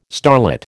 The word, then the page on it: starlet: American English